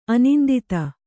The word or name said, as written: ಅನಿಂದಿತ